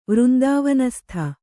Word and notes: ವೃಂದಾವನಸ್ಥ: Kannada

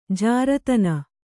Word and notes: ಜಾರತನ: jāratana

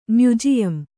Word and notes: ಮ್ಯುಜಿಯಂ: Kannada

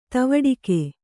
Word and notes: ತವಡಿಕೆ: tavaḍike

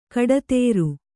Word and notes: ಕಟ್ಟೊತ್ತರ: kannada; kaṭṭottara

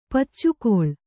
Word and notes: ಪುಚ್ಚುಗೊಳ್: puccugoḷ

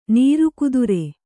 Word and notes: ನಿರ್ಗೋತ್ರ: nirgōtra

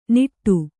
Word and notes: ನಿಟ್ಟು: niṭṭu

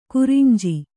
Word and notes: ಕುರಂಜಿ: kannada

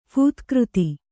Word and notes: ಫೂತ್ಕೃತಿ: Kannada

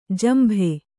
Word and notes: ಜಂಬಿ: jambi

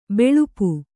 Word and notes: ಬಲ್ಪು: Kannada

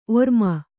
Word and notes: ಒರ್ಮಾನ: ormāna

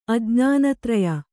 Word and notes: ಅಜ್ಞಾನತ್ರಯ: kannada

